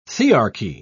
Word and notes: thearchy: British English

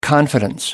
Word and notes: confidence: American English

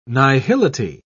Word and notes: nihility: British English

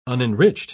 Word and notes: unenriched: British English

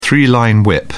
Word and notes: three line whip: British English

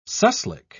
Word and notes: suslik: British English

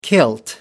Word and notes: kilt: American English

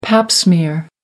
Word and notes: pap smear: American English

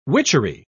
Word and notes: witchery: British English

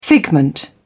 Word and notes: figment: British English

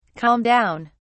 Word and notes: calm down: British English

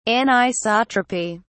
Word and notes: anisotropy: British English

